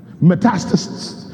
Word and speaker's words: metastasis: Metastasis.